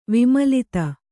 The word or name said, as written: ವಿಮಲತೆ